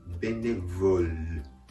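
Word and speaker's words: bénévole: ゃにならないように気をつけてくださいベネヴォルベネヴォルですそしてあえてで言うと語末ネイルこれは英語ネイルとは違って音を止める感じですベネヴォルベネヴォルルと発音しながらこの単語の発音を止める形ですねベネヴォルベネヴォルです。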